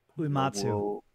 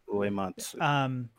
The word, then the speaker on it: Uematsu: Uematsu. – Uematsu.